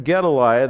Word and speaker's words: Gedaliah: Now Shephatiah, the son of Mattan, Gedaliah, the son of Pashur, Jukal, the son of Shelemiah, and Pashur, the son of Malchiah.